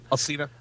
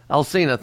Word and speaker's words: Alsina: It's Currency featuring Lil Wayne, August um, Alice, Alice. How do you how do you pronounce his name? – Alsina. – Alsina, thank you.